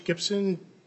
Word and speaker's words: Gipson: And my name is George Gibson, G-I-P-S-O-N.